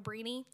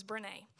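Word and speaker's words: Brené: I actually YouTubed this to see how to pronounce it because I was calling her Breany. Apparently, her name is Brene.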